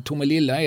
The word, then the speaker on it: Tomelilla: Och eh, hävdade ju då med någon sorts liksom, historikerns rätt att alltså, Tomelilla är det korrekta uttalet.